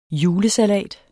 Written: julesalat

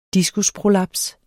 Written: diskusprolaps

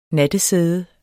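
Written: NATTESÆDE

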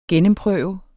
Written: gennemprøve

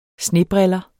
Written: snebriller

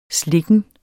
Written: slikken

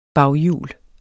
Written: bagjul

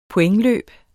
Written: pointløb

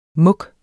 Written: muk

